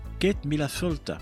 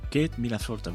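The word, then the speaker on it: Céad míle fáilte: Alors je vous dis 100 000 fois la bienvenue en gaélique, ça s'écrit Seat Milfelt, mais on prononce Kate Milafolta. Kate Milafolta,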